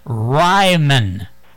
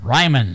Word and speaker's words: Ryman: It's just, you didn't pronounce it right. – Didn't pronounce it right. – It's well, I have a speech impediment, that's why. – Yeah, okay. But now, it correct it by saying Ryman. – Ryman? – R-Y-M-E-N. Ryman.